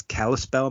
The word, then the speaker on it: Kalispell: is Kalispell, Montana. Mm-hmm. I could be pronouncing that wrong. – No, that's correct. – Is it? Okay. – Yeah, you did it.